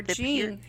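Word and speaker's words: Jane: They even did a seance at the White House for First Lady Jean Pierce. – Yeah. – Or Jean, however you want to pronounce it. Jean would be the French pronunciation.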